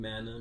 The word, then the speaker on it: Mana: So it's John Manna Music. My last name is spelled M-A-N-A. John Manna. – John with the H. – J-O-H-N. – Not that J-O-N.